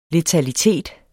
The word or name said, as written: letalitet